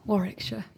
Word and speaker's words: Warwickshire: But you grew up in Warwickshire? – Warwickshire, yeah.